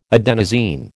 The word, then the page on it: adenosine: American English